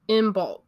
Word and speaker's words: Imbolc: So, for this episode, we're going to be talking about Imbolc.